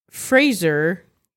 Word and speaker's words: Fraser: This is, it's Fraser. You shave with a razor. That's from Brendan himself. – Really? – That was Fraser. Fraser rhymes with razor.